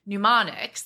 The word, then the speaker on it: mnemonics: You have a number of mnemonics that you've set up in the book.